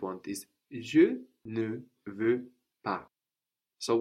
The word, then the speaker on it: pas: But then we also have this pas on the end, which is spelled P-A-S. Pas, P-A-S, is related to pass, passage, passage, meaning something like step, But it has just one S, and that S is silent, as we hear now. Pas. Je ne veux pas.